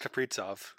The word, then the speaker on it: Kaprizov: It's actually pronounced Kaprizov.